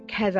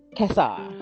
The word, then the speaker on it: Cassar: And everybody, this was Nathan Cassar. I said it right this time. Yay! – Ooh, so close. Let's try one more time. Cassar. – Cassar. – Yeah.